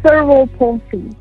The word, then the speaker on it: cerebral palsy: How do I say it correctly? – It's uh, called cerebral palsy. So, so cerebral palsy.